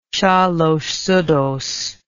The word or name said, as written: shalosh seudoth